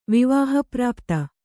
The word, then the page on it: ವಿವಾಹಪ್ರಾಪ್ತ: vivāha prāpta